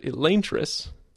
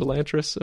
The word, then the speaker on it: Elantris: I guess he pronounces it Elantris, which could have, you know, that's odd. But he's cool with us calling it Elantris, apparently, so we're going to do so.